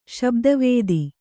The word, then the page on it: ಶಬ್ದವೇಧಿ: śabda vēdhi